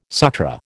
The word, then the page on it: Sutra: American English